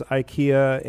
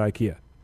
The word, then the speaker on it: IKEA: There's an app called Furnish that actually has IKEA and... By the way, our friends from Zurich say it's not IKEA. – What is it? – It's IKEA. – IKEA. – That's how they say it in Europe.